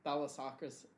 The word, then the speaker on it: thalassocracy: Thalassocracy.